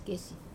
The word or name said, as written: kesi